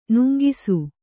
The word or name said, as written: ನೂಂಕಿಸು